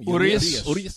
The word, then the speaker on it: Urias: Urias.